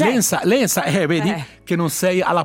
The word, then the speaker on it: Lensa: Cioè, come Lensa, senza la Z, ma con la S.